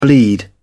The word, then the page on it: bleed: British English